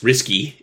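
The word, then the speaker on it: Rsky: He's also the creator of the Risky, spelled R Sky, pronounced Risky, implementation of AppProto in Rust.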